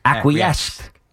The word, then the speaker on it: acquiesced: It's acquiesce. – What am I saying? – Est with a T. – Acquiesced. – Yeah, no, I think it's acquiesced.